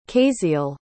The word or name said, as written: caiseal